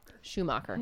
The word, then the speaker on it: Schumacher: Did I say that right? Okay. Schumacher. There we go.